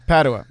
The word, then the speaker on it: Padua: Padua.